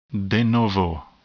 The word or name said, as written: de novo